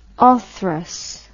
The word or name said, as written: orthrus